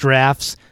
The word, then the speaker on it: draughts: It's D-R-A-U-G-H-T-S, so draughts, but it's pronounced drafts. There's English for you.